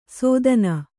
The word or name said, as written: ಸೋದನ